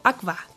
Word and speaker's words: Aqua: Hoy nos trasladamos a Wolfsburgo, en Baja Sajonia, para visitar el aclamado restaurante Aqua, o Aqua, como se pronuncia en alemán.